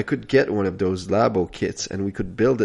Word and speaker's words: Labo: I just want to mention first off that I heard yesterday that the reason they called it Nintendo Labo was because lab in Japan is pronounced Labo, and that way it's easier for them to call it Labo everywhere.